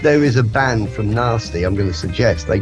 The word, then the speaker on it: Nesty: It's spelled N-E-S-T-Y. It might pronounce Nasty, but it's N-E-S-T-Y, Nasty.